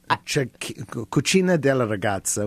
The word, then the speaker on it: Cucina della Ragazza: And then I went to, you're going to have to help me pronounce this, because I'm Cucina del Ragazza. – Cucina della Ragazza, which translates as Kitchen of the Young Girl.